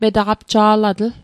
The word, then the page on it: metaqapja'latl: Mi'gmaq